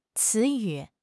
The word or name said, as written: ci2 yu3